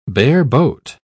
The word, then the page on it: bareboat: British English